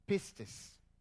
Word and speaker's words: pistis: And the noun form is derived from the Greek pistis. Pistis. Did you hear that? Amen. The verb form is derived from the Greek pisteo. Pistis, P-I-S-T-I-S.